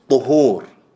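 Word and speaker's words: طُهور: That's why earlier when I mispronounced it and I said طهور, I said no. Why? That's a whole other word. That means something totally different. What we're talking about is طهور with ضم.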